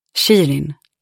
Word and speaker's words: Shirin: ursäkta mig om jag uttalade fel, men är det Sharon? Han pratade med mig, såg mig rakt i ögonen. Shirin, sa jag.